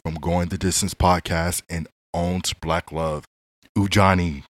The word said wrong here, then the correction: Kunjani: Kunjani. Kunjani with that strong K.